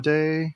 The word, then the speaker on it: Ayoade: I don't know how you say his name, but the dude from IT Crowd. – Ayoade. Richard Ayoade.